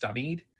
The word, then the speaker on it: David: It's spelled David because the Spanish language alphabet spells it that way, but it is pronounced David.